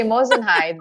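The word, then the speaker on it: moissanite: Now I know to say Mosinite, but I actually had no clue.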